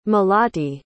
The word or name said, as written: malati